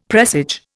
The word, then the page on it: presage: Indian